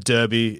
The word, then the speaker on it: Derby: I'm born and bred West Australia, and I've grown up in the great state, and the way I've always said Derby is to pronounce it that rhymes with Kirby, the Nintendo character.